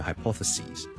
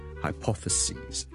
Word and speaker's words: hypotheses: of crises， so one crisis， two crises，呢个原因咧系因为来自一个希腊文嘅一个 plural，咁所以咧我哋就会 sis 变咗做 ses，仲有其他字都系咁嘅，譬如 hypothesis。一个假设 hypothesis， hypothesis， hypothesis， hypothesis，亦都系来自希腊文嘅一个字。 So hypothesis， hypothesis plural 咧，我哋系 sis 转咗做 ses，就变咗 hypothesis， hypothesis， hypothesis，记住个发音，OK？由 sis 转咗做 sees，你当好似系。 C-S-E-A 或 S-E-E 嗰个 C，跟住再加多个 S， so singular hypothesis， plural hypotheses， singular crisis， plural crises。